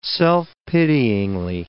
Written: self-pityingly

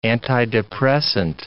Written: antidepressant

